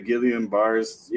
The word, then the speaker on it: Guillain-Barré: Guillain-Barré syndrome? Yeah, the Guillain-Barré.